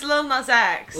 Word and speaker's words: Lil Nas X: Yeah, it's Lil Nas X.